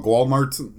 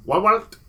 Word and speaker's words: Walmart: I believe out there they call it Walmart. – Walmart. It's like Mart, but with like the accent on the A basically is the only difference.